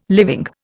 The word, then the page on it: living: Indian